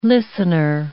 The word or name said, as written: listener